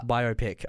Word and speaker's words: biopic: It's biopic, okay? Because you're making a biography picture.